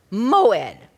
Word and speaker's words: moed: And those dates are called in the Hebrew, and I do think you should learn this word, moed. Moed. You can spell it M-O-E-D.